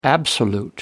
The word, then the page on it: absolute: American English